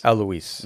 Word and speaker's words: Alois: You're talking about, how do you pronounce his name? – Alois. – Mm-hmm. Yeah. So the German.